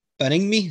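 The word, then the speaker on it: Baningime: Other one me and Gunnar have got is Beningme. Is that how you pronounce his name, Tom? Hearts player. Do you know? – Yeah. – That was good. Bang on then.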